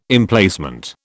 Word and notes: Emplacement: British English

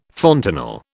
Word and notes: fontanel: British English

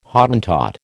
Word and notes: Hottentot: American English

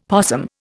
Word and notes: possum: Indian

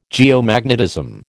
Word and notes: geomagnetism: American English

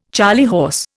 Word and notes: charley horse: Indian

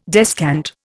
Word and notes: descant: Indian

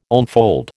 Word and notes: onefold: American English

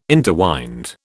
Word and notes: interwind: British English

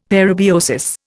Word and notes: parabiosis: Indian

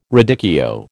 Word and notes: radicchio: American English

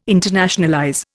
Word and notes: internationalize: Indian

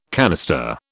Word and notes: canister: British English

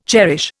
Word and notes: cherish: Indian

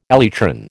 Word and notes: elytron: American English

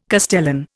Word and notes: castellan: Indian

